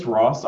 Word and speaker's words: Ross: Is it Chris Ross or Voss? – No, it is. That's correct. It's Ross, R-O-S-S.